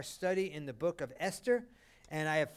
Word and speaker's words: Esther: And she said, just take the H away. And then Mark Hester came up. He said, say my last name, Hester. He said, now take the H away, Esther.